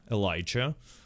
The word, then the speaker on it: Elijah: His name is Elijah.